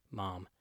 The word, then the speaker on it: mom: We say it like when a dentist says, open your mouth and say, ah. We use kind of an AH sound, mom.